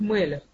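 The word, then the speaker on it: мелех (מלך): Первая буква этого – это мох, лев, кавет, Мем ламет хав читается как мелех.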